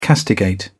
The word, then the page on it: castigate: British English